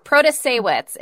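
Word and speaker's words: Protasiewicz: Protasewicz is what I believe was the actual pronunciation. Protasewicz.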